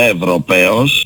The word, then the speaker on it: Ευρωπαίος: Είμαι Ευρωπαίος, τονίζω το παίος.